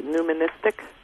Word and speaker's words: numismatic: that's something I'd never seen in a how do you say numismatic? – Numismatic.